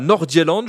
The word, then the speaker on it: Nordjylland: Je ne sais pas si je prononce bien, peut-être que vous êtes meilleur que moi en danois. Surpris ou pas spécialement de voir le Danemark à ce niveau-là du tournoi? – Alors, le FC Nordjylland, non, la prononciation était pas mal du tout.